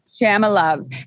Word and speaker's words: Shamalov: So Ellen Catherine Shamala, is that the correct pronunciation? – Shamalov.